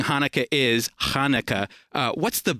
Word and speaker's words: Hanukkah: And a lot of Gentiles have difficulties with ch. So even though it would be it could be spelled with a ch, you'll have people say Hanukkah all the time. I say Hanukkah. – Okay, so there's a little guttural there.